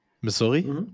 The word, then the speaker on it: Missouri: Do I pronounce it like the state? Missouri Williams? Yeah. Okay.